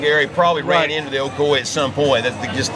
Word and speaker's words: Ocoee: The Ocoee River. O-C-O-E. Just like they pronounce it here in Florida.